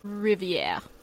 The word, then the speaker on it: Riviere: Riviere. That's how you say it, by the way.